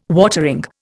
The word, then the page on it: watering: Indian